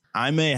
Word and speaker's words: Jaime: Jaime Jasquez is the pronunciation, but yeah. We know the media. They're going to say Jamie Jasquez.